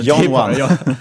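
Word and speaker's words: Janome: Men Janone, Jan number one.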